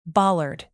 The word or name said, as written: bollard